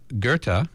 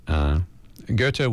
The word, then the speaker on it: Goethe: So Goethe, whose name is spelled G-O-E-T-H-E, which, of course, for many decades, I think I pronounce as Goethe, but you know.